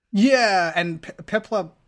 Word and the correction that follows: Piplup: however you pronounce that, Piplup. – Penguin. It's like Piplup.